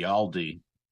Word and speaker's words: Aldi: How do you pronounce Aldi.